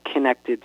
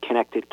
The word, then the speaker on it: Kinected: The band spelling is The Connected, spelled K-I-N-E-C-T-E-D. It's a combo of kinetic energy and connections, connection with people.